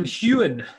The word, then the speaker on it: Heughan: Sam Heughan.